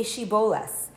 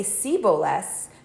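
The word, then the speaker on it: shibboleth: They would ask them to say Shiboles. And if they would say Siboles, because they knew that the people from Ephraim, they were not able to pronounce it in that way, they would know that they were from Ephraim, they would grab them, and they would kill them.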